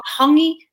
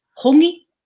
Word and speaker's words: hongi: It's not hangi, it's not hangi. It's hongi. Don't talk about something if you're not prepared to say it correctly.